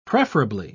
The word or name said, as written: preferably